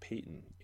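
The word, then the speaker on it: Paton: But it more seemed like with Patton that I'm pronouncing his last name wrong. It's Peyton. It's pronounced Peyton, AJ.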